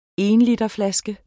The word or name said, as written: ENLITERSFLASKER